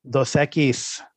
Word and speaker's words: Dos Equis: So, and, and, this is brought to you by Dos X. H- how do you say that? Am I saying that right? – Dos Equis.